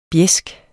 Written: BJESKERNES

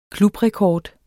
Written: klubrekord

